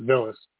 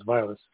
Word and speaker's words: Vilas: I always called it Villas, but I believe the correct pronunciation is Vilas County Bike Trail.